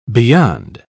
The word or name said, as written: beyond